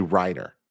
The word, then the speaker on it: Reiner: Pizzazzle, great name, says Tim was saying Andrew Rayner's name wrong. Wait, yeah, I was saying Rayner. It's actually Reiner. So Andrew Reiner.